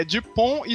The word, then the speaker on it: Dupont: Não, é Dupont e Dupont. Eles falam absolutamente iguais em francês, é de propósito. O francês não pronuncia a última consoante.